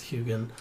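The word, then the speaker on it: Heughan: His name is Sam, I believe it's pronounced Hugen.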